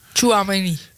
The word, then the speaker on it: Tchouaméni: Want die twee Valverde en Tchouaméni. Zeg ik dat goed? – Tchouaméni.